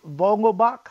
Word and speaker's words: Vogelbach: How did you – this Daniel Vogelbach, how you say his name? I'm not sure how you say it. – That's right. You got it.